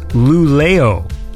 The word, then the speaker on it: Luleå: So this track is called Lulea, like L-U-L-E-A, which is actually a place. So there's a place in Sweden, and Wikipedia says it's pronounced Luleå. Does that sound right to you? – Luleå.